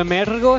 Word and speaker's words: Emergo: Pero el Mergo está súper bien pronunciado, ¿qué sí? – Sí.